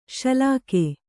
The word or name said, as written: ಶಲಾಕೆ